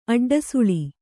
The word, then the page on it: ಅಡ್ಡಸುಳಿ: aḍḍasuḷi